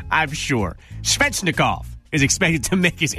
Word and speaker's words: Svechnikov: The Wings' top prospect, Evgeny Slichenov, is, I probably just butchered that name, Isaac. You want to take a stab at that? Take a stab at that name. – Svechnikov? – Svechnikov. Yeah. Okay, you cheated.